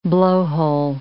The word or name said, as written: blowhole